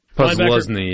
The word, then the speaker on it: Posluszny: Posluszny, yeah. – Yeah, linebacker at Penn State, early, mid-2000s. – The way it's spelt versus say it just defies all rules of the English language, but that's okay.